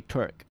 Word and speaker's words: twerk: 他刚好特别纠正我的发音，T W E R K twerk。